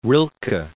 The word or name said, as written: Rilke